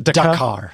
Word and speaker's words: Dakar: Dakar.